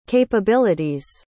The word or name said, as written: capability's